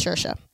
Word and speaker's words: Saoirse: Well, it looks like Source, yeah. But it's Saoirse Ronan in Little Women.